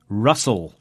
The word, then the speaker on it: rustle: Rustle describes the sound of the wind. A rustle is a soft, dry, moving sound. It's spelt R-U-S-T-L-E. And in pronunciation, the T is silent, so it's rustle.